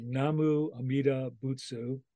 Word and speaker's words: Namu Amida Butsu: And so please say it again. Nama Amida. – Namu Amida, Amida Butsu. That's Butsu. The Japanese form. The Chinese is kind of more difficult to pronounce, but okay. Namu Amida Butsu.